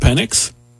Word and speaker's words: Penix: Is it pronounced Penix? – It is on this show.